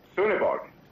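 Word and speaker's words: Sønderborg: Y claro, el Sonderborg es la O con el palito así atravesado. – ¿Sí? – Y esa O llevo 21 años y todavía no la puedo pronunciar. Porque es una cosa entre O y U.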